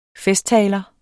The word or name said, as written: festtaler